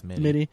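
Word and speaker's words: Mitte: And just in RJ, I don't know how to pronounce Mitty. – His last name. – I think it's Mitty.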